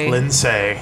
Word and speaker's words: Linsay: I'm on Instagram at Linsay SD, but Linsay is spelled Linsay.